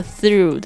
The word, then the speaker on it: through: 所以呢特别要注意一下这个 through 的发音， T-H-R-O-U-G-H 这个 through，然后那个 G-H 不发音，就是过去的意思。